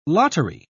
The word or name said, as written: lottery